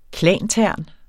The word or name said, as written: klantern